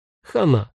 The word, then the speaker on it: Хана: Только лучше с одной буквой «Н» и ударением на последнем слоге. Хана. Мне хана.